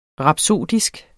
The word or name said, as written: rapsodisk